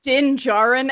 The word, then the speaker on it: Din Djarin: His name's Din Djarin, and it's spelled. It's so dumb. – It's a silent D. – Yeah, it's a silent D. It's like Jin.